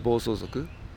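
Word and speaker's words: Bosozoku (暴走族): how do you pronounce it again? I think I'm doing it wrong. – Bosozoku. – Bosozoku.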